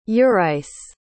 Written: urice

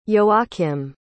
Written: joachim

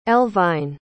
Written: elvyne

